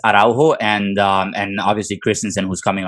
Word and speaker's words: Araujo: So at this point, you know, we're expecting Christensen to come in, you know, and we have, correct me if I'm wrong on this pronunciation, but Araujo. – Yeah, Araujo. – Araujo.